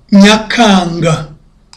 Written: nyakanga

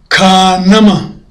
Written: kanama

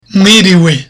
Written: mwiriwe

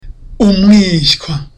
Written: umwishywa

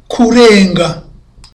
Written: kurenga

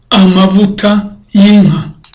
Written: amavuta y'inka

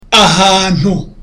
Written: ahantu

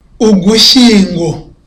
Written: ugushyingo

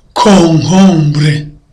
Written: konkombure

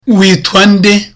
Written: kwitwa

